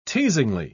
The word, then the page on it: teasingly: British English